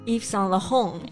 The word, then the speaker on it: Yves Saint Laurent: 第二个 Yves Saint Laurent. – Yves Saint Laurent. – Yeah. – Pardon my French, but it doesn't ring a bell. 这个什么意思啊？ – Actually, you know this one very well. 你最喜欢的那支口红不是 YSL 的吗？ Well, this is how you say YSL in French. – Ah, good to know. Yves Saint Laurent.